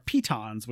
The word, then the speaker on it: pitons: You can have a backpack, bedrolls, something that has come up in all of my campaigns are pitons, which I learned that was the pronunciation.